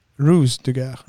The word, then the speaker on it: Ruse de Guerre: Och då är det filmen som heter Eh, Rouge de Guerre. Är det så man uttalar det? Rue de Guerre. Rue de Guerre. Och vad betyder det? Krigsgator. Krigsgator. Ah, nej, det är RUSE. Ruse de Guerre.